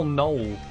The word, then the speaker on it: knoll: How do you even spell knoll? – K-N-O-L-L. See, it doesn't even make sense. It's got a silent K.